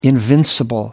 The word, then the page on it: invincible: American English